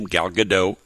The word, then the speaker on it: Gal Gadot: In a minute, I'll share my interview with Gal Gadot. That's how she told me that you pronounce her name, Gal Gadot.